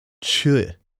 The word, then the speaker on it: Choi: I had a 6th-grade Korean boy whose name on my roll was spelled C-H-O-I. I asked him, is it Choi? Is it Choi? He responded, no, Chu. Or that's what it sounded like. So I said, Chu.